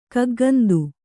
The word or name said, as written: ಕಗ್ಗಂದು